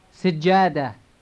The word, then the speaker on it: سجادة: سجادة. سجادة. سجادة. – Repeat it, and make sure to double the J sound.